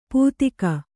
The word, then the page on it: ಪೂತಿಕ: pūtika